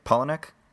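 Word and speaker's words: Palahniuk: This film, Chuck, how do you say his name? Polonek? – Yeah.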